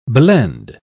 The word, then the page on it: blende: British English